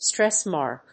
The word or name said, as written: stress mark